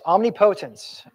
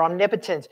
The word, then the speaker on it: omnipotence: Yes, omnipotence or omnipotence. Either way you want to pronounce it, that's right.